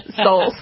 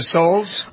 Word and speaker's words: Soules: Meanwhile, who is Chris Soules? S-O-U-L-E-S. – Souls. – Or Souls. Oh, that's how you pronounce it, Souls? – Or Soules, whichever Soules.